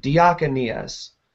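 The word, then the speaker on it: Diakonias (διακονίας): That word ministry in the Greek is the word diakonias. Sorry, let me say that right.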